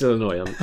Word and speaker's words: Illinois: No, I do know it's pronounced Illinois.